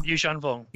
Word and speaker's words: Yuuzhan Vong: Like, I just want them to do the sort of the the you uh oh, I can't pronounce this properly. The Yuzan Vong, Yuuzhan Vong, yeah,